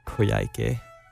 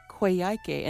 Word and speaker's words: Coyhaique: It's Coyhaique. – Coyhaique, and this is in Patagonia? – Patagonia, southern Chile.